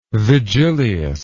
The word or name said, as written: vigilius